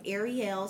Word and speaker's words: Ariel: But it is Ariel, spelled AIRREYELL, just so people can pronounce it right.